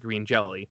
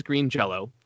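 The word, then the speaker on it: Green Jellÿ: For example, and I'll relate this to Weird Al, Green Jello, who is often mistakenly pronounced, their name is often mistakenly pronounced Green Jelly. it's spelled with a Y with an umlaut over it, which is a character that doesn't exist in any language, and so that's pronounced like an O, according to them.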